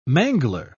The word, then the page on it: mangler: British English